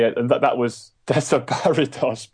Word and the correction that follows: Desaparecidos: but I don't know if that's how you say it. – Desaparecidos. – Desaparecidos. I should just copied you.